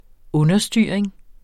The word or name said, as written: understyring